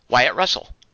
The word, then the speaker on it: Wyatt Russell: Wyatt Russell. – Okay, you're saying it right. I thought you were saying white.